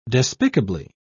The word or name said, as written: despicably